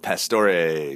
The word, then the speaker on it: Pastore: I like your last name because it's I put a little extra emphasis there on the third syllable of Pastore, because it's, what do we have here?